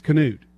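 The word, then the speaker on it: Knute: It's not nude, it's Canute.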